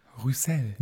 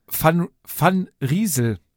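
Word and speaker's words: Van Rysel: Also wir klären ein für alle Mal auf und es hieß Van Riesel.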